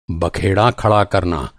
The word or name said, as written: बखेड़ा